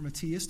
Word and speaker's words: Matthias: How popular was Matthias? Or Matthias, depending on your preferred pronunciation.